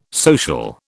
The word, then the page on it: social: British English